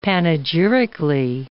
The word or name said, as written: panegyrically